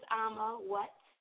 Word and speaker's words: Amma Whatt: It's Amma Whatt.